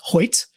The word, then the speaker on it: Hoecht: Yeah, and I found out it's pronounced Hoyt. Hoyt. Which I don't know. H O E C H T makes a Y sound, but go on. However, from what I understand, it's Hoyt.